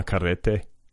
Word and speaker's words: Carrete: And by the way, did you hear me attempt to roll my R's there when I said Ana's name? Ana Carrete? That's the proper pronunciation.